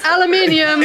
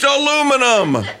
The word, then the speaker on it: aluminium: It's aluminium. – It's aluminum. – Aluminium. There's an I.